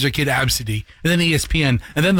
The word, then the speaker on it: Abcde: Number one is Absidy. And it is spelled ABCDE. And it's pronounced Absidy.